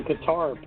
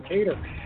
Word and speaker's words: Kattar: Jeremy Stephens, he will take on Calvin Kattar. I don't know why we used to butcher his name and call him Qatar. Apparently, some people still do, but it's Calvin Kattar at a minus 255 favorite.